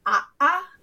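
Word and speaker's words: a'a (ʻaʻā): So an Okina is what is in the language world called a glottal stop which is um, if you have two vowels next to each other and they're meant to be pronounced separately. Um, for example, the pokey kind of lava is called a'a, and okay.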